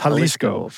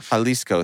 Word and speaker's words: Jalisco: It's Jalisco. – Jalisco.